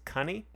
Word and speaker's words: coney: Supposedly, one of the reasons we stopped saying Coney instead of Bunny was it was actually pronounced... Like, it rhymed with honey. It was cunny, which sounds like slang for...